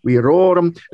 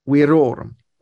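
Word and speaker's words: virorum: Some people have the tendency to accent the last syllable, like say weiri, werorum. No, it's weiri, werorum, weiris, weiros, weiris. You never accent the last syllable of a Latin word except the word ad hoc.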